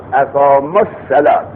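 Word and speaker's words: أقاموا الصلاة: اقام الصلات. پس در تلفظ واو را تلفظ نکردیم، اقام الصلات بی واو، اقام الصلات.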